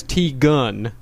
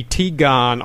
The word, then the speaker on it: Teagon: Says, God damn it, my name is pronounced as T-Gun with a U sound, as in you suck at reading, God damn it. Look, son, if your name is spelled T-E-A-G-O-N, it's going to be T-Gon on this show.